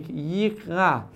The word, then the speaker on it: יקרא: לבטא, לשים לב, לבטא את היוד. לא חייב להפריד ממש בין המילים, אלא פשוט לשים לב שאני מבטא יוד חיריק, יקרא, אשר יקרא אתכם באחרית הימים.